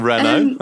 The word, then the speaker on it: Renault: Renault.